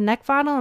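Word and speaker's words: Neckvatal: You can also find me on LinkedIn at Brenda Neckvottle, and my last name is spelled N-E-C-K, like the thing you want to choke, V as in Victor, A-T-A-L.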